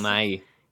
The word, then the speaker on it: Bill Nighy: Bill Nighy.